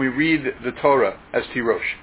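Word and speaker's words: Tirosh (תירוש): It's written in the Torah Tirash without any Vav, right? The word, he's doing word games. But we read this word according to the pronunciation handed down by tradition as Tirosh.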